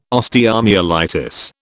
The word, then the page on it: osteomyelitis: British English